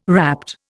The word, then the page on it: rapt: Indian